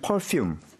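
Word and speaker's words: Perfume: 파퓸? – 네. 영어로 하면 퍼퓸. – 아, 일본식 발음이군요.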